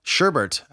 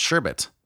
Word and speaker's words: sherbet: You know, Sherbert actually is pronounced Sherbet. Or at the beginning of the episode, I thought maybe I would drop a fun fact every time he coughed. It's Sherbet. There's only one R.